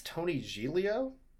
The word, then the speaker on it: Tony Giglio: I think it's Tony Giglio. Is how you pronounce it? It is.